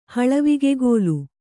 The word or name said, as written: ಹಳವಿಗೆಗೋಲು